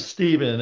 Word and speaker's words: Stephen: Well, my name's Stephen, S-T-E-P-H-E-N. I can't tell you how many people call me Stephen. And that's actually correct, but that's not how I pronounce my name.